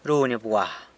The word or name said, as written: runy bgwa